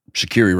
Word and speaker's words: Shaqiri: And Steve, you know what Shaqiri rhymes with, right? – Yes, I do. – What is it? – Daiquiri. – Daiquiri. – Daiquiri. That's the correct pronunciation.